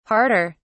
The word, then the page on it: harder: British English